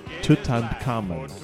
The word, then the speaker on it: Tutankhamun: That would be Tutankhamun.